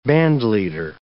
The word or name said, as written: bandleader